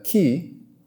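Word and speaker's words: chi: And, you know, the letter X is also, in Greek, is pronounced key, and it is the first letter of the word Christ.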